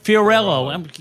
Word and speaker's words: Fiorillo: But it's the First Ladies of Las Vegas Entertainment, which stars Kelly Clinton, who's a big name around here, and Elisa Fiorillo. Am I pronouncing it right? Fiorello. You got to think like the old mayor of New York.